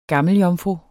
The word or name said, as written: gammeljomfru